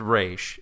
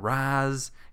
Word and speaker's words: Ra's: And I'm saying Ra's because it's friggin' pronounced Ra's. If you watch the Batman animated series, they call him Ra's. It's not Raz. He's not razzing anybody.